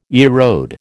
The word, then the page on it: erode: American English